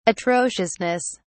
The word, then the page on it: atrociousness: British English